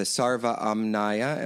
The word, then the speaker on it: Sarva Amnaya: Okay, so let's now move a little bit into a tradition that you talk about a lot in your book, which you, the Sarva Amnaya, am I pronouncing that correctly? – Correct.